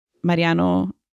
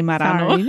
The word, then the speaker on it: Mariano: Dude, now I'm tripping because I feel like I'm going to say Marano. – Sorry, no. – You said it right. – I know I did, but in my head, it sounded like Marano.